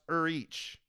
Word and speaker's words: Erich: It's pronounced Erich, like Aaron with a air. – Wait, Aaron-ch. are you being for real? – Oh, man. Like Aaron, but C-H instead of N.